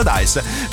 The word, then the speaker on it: Dice: Ingresso gratuito però con prenotazione sulla piattaforma Dice, che si scrive Dice ma si pronuncia Dice, mentre per tutte le altre informazioni vi segnalo i nostri social Radio M2O e il nostro sito m2o.it.